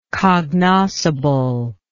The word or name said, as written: cognoscibility